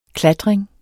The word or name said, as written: klatring